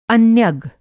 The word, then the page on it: अन्यग: Hindi